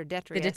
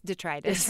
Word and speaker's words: detritus: The leftovers are detritus. – It's detritus.